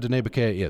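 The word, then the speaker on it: Diné Bikéyah: Uh, Mr. Grayeyes, am I pronouncing the name of your organization correctly? That's perfect. Oh, okay, great. I'm I'm glad. uh, tell me what Utah Dine Bikeyah is.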